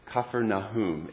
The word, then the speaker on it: Capernaum: The Hebrew, the Aramaic, the Arabic, the way to say it is Capernaum. It means the village of Nahum, like Nahum the prophet. And it's written two different ways, Capernaum and Capernaum.